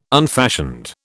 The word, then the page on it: unfashioned: British English